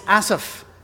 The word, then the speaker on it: Asaph: And I know a lot of people pronounce it Asaph, and I did too, until we had some Egyptian members of our church up in Detroit, and they would laugh every time I called him Asaph. And they said, no, it's Asaph. And I went, I will now pronounce it correctly.